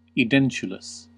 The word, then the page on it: edentulous: American English